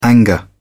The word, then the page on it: anger: British English